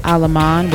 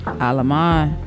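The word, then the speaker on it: Allemand: Aleman, without, she says, don't pronounce the D. Aleman, 13 points, 5 assists, 4 rebounds.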